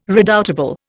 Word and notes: redoubtable: Indian

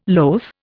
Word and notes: loath: Indian

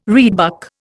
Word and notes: reedbuck: Indian

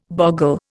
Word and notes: bogle: Indian

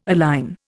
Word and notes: align: Indian

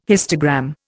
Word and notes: histogram: Indian

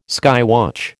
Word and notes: skywatch: American English

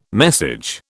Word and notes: message: British English

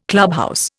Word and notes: clubhouse: Indian

